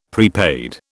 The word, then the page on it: prepaid: British English